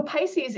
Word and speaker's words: Pisces: Can you touch on Pisces, if I'm pronouncing it correctly? – Yep. So Pisces is the name of our new basic science curriculum that I was describing before.